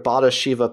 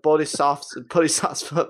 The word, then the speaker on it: Bodhisattva: but integrating it with a sort of Bodhisattva path, you know, a Buddhist sort of, I'm probably not pronouncing that word right, but Bodhisattva path.